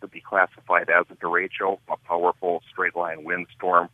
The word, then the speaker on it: derecho: Derecho, D-E-R-E-C-H-O, Spanish for, well, straight line.